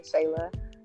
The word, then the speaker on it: Shayla: It doesn't stand for doll, but D as in doll, Tumbling, like you pronounce it, Tumbling, Shayla, S-H-A-Y-L-A.